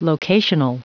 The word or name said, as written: locational